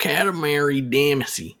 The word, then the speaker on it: Katamari Damacy: Katamari Damacy.